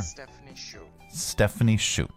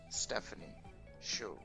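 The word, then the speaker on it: Stephanie Hsu: Stephanie Shu. – Is that right? – Yeah. – Stephanie Shu. – Thank you. – Getting a little testy with you there. – He really was. Stephanie Shu.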